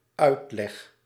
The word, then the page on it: explication: France; [ɛks.pli.ka.sjɔ̃]